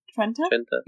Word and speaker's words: Trenta: They also don't have Trentes over there. – Oh, I said it wrong, didn't I? Trenta. – Trenta? Yeah, Trenta, with an A.